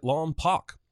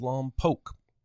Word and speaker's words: Lompoc: Watson says he's from Santa Maria near Lompoc, pronouncing it Lompoc. If he actually lived there, he'd know it's pronounced Lompoc.